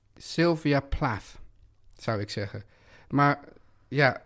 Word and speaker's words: Plath: En die zei dat je in dit geval misschien zelfs de TH niet als een TH moest uitspreken. Dus nou ja, dat laat dat maar. – Sylvia Plath, Plath. – Plath, ja.